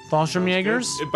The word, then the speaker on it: Fallschirmjäger: It's the one with the German Fallschirmjägers. Is that how you say it? Yeah. Fallschirmjägers.